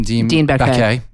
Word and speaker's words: Dean Baquet: You're the editor-in-chief, how do you pronounce his name? – Dean Baquet.